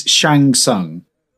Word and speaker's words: Shang Tsung: He's Shang Tsung.